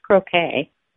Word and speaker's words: croquet: Yeah, this is just a minor comment, but I just wanted to tell you, you were pronouncing that right all along at first. It's croquet.